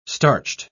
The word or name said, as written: starched